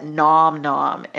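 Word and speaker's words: Gnom Gnom: I find delicious low-carb recipes at Nom Nom, and it's spelled GNOM-GNOM. And they have great recipes on there.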